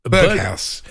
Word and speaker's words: Berghuis: Is it Berghuis? Berghaus. Stephen Berghaus.